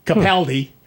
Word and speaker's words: Capaldi: Peter Capaldi, as they apparently pronounce it in England, Capaldi, is the new Doctor, and we're all waiting.